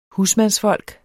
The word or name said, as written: husmandsfolk